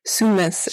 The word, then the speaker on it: Sumus: Is that how you say it? Sumus? – Sumus. It means I am, yeah, in Latin.